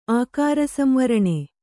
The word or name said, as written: ಆಕಾರಸಂವರಣೆ